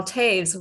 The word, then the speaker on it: Toews: His name is Karl Toews.